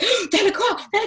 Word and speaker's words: Lacroix: And you can't pronounce Christian Lacroix normally. Like, you have to say, like, it's Lacroix, darling. It's Lacroix. Is it Lacroix? Lacroix. – It's like an exhale, isn't it? Lacroix.